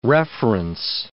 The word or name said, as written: reference